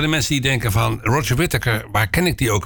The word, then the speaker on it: Roger Whittaker: Dus dan, nou hebben we niemand minder dan Roger Whittaker. Ik zeg het goed. Hans, ik moest het per se beloven dat ik het goed zou uitspreken. En niet Roger Whittaker. Roger Whittaker, en die heeft het liedje geschreven I Don't Believe in If Anymore.